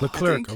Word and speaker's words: Leclerc: Oh, Leclerc, okay.